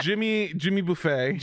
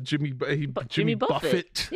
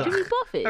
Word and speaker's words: Buffett: Because we have Jimmy Buffet. Instead of Jimmy Buffett. – Yeah, ugh. Jimmy Buffett. – That's not how you say that.